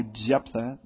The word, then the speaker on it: Jephthah: And Jephthah, by the way, or Jephthah, Hebrews pronounced J as a Y.